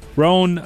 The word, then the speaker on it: Roan: It looks like rising star Sabrina Carpenter and Chapel Rowan, I always say her name wrong, Roan, they both secured wins, while Taylor Swift and Billie Eilish were notably absent from the winners list.